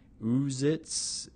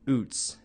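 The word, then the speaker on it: Ouzts: When I read his last name for the first time, and that's spelled O-U-Z-T-S, I pronounced it something like Uzitz, what? I had no clue. Utz, Ann corrected me.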